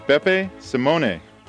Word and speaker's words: Beppe Scimone: And my name is Beppe Simone. – How do you spell that? – B-E-P-P-E-S-C-I-M-O-N-E.